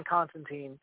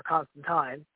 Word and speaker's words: Constantine: And so for those who know John Constantine or Constantine, because we had the correct pronunciation given to us in the show, whereas, you know, the other adaptation has been pronouncing it wrong this whole time.